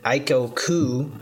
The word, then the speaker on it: Aiko Koo: Aiko Koo. I think I'm pronouncing that right. That's what it looks like. – Aiko is correct. Koo is correct.